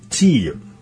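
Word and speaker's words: 鶏油: で、蓋の上に別でトリュフチーユ。鶏の油と書いてチー油ですね。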